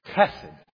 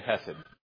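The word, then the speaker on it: chesed (חסד): If we said it in Hebrew, it would be chesed. Chesed. We'll just say hesed, okay? That is the word, hesed.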